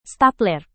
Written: stapler

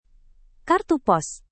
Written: kartu pos

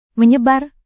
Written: menyebar

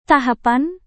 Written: tahapan

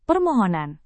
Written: permohonan

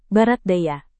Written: barat daya

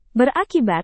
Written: berakibat

